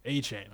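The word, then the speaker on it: Achane: A-Chain. – Okay. Yeah. Devin A-Chain.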